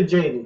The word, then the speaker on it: Tjaden: It's actually Jaden. The T is silent, so.